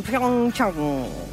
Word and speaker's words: Pyeongchang: In Pyeongchang.